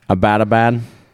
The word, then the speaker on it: Abbottabad: How do you say that fucking word? – Abbottabad, Pakistan.